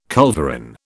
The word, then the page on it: culverin: British English